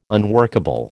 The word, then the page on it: unworkable: American English